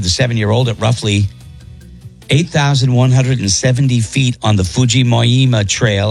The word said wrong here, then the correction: Fujinomiya: I probably butchered that and I Fujiyama. – Apologize. No, it's Fujinomiya.